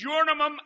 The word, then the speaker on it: Urim: But the correct pronunciation is Urim and Thummim.